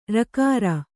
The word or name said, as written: ಱಕಾರ